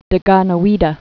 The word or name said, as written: Deganawidah